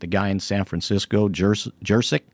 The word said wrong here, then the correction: Juszczyk: Oh, is it Juszczyk? – Juszczyk, sorry. I knew it. Kyle Juszczyk.